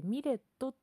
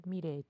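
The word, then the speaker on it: milet: えー、っと、ミレイさんっていうアーティストご存知でしょうかローマ字でミレットって書いてミレイって読むん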